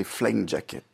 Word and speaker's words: flying jacket: Je remarque, que vous avez parfaitement prononcé flying jacket. Oui, flying jacket. Donc, c'est un mot anglais que vous prononcez très bien grâce à une chanson française.